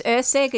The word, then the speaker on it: Erceg: Now, Jeannie, you tell me your name is E-R-C-E-G. How is that pronounced? – It's Erceg. It's Yugoslavian.